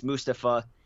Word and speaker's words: Mustafa: I think it was last week he corrected everyone, saying that he wants everybody to know his name, that now, like, for the past years, they've been saying his name wrong and everything like that, and it's Mustafa.